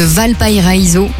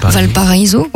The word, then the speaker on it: Valparaíso: en passant par les rues colorées de Valparaíso. Ouais, je l'ai pas très bien prononcé, je crois. Valparaíso, ouais.